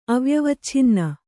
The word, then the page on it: ಅವ್ಯವಚ್ಛಿನ್ನ: avyavacchinna; Kannada